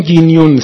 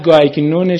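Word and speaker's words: Guignon: ولی آقای دکتر من چک کردم گینیون صحیحه، آره، نه گایگنون شما نه گیگنون من، یعنی اون چیه؟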